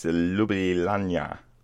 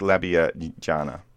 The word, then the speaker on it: Ljubljana: No, it's Ljubljana. – I don't even understand what you've just said. – It's like labia jana. There you go. – Labia vagina. – Ljubljana.